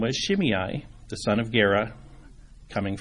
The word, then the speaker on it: Shimei: And that person's name is Shimei. That's the English pronunciation. Interestingly, when I was looking it up on the internet, there's a Hebrew pronunciation. So I thought, for Peter's benefit, the pronunciation in Hebrew is just the EI becomes a long A sound. So Shimei.